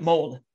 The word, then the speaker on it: Molde: Molde. That's it. Molde. Mm-hmm. Molde? Is that how you pronounce it? – Yep.